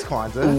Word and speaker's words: Kwanzaa: Joyous Kwanzaa.